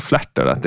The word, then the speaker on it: Flattered: När vi körde igång då var det vissa som undrade varför vi hade döpt oss till Flärter. det, det är som Flattered, som man tänker på Ullared. Men det är alltså fel. Flattered är det. Och det stavas F-L-A-T-T-E-R-E-D.